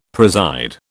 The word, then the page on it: preside: British English